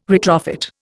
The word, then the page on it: retrofit: Indian